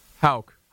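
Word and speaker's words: Hauch: Am I saying that right, Brian? Hawk? – Hawk, but everyone gets it wrong. – Hawk. How would you pronounce that, Toby? H-A-U-C-K. H-A-U-C-H, rather.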